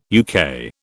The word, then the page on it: UK: British English